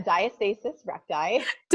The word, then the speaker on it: diastasis recti: Did I even say that correctly? I've never said it out loud. – Okay. A diastasis recti.